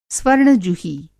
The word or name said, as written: स्वर्ण-जुही